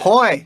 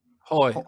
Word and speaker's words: Hoj: How do you say H-O-J? – Hoy. – How do you say that? Hoy.